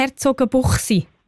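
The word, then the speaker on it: Herzogenbuchsee: Der kommt von Herzogenbuchsi. – Merci vielmals, danke, danke. – Habe ich es eigentlich richtig gesagt? Herzogenbuchsi? – Ist genau, so? Ja, das ist so. Ja.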